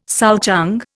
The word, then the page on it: souchong: Indian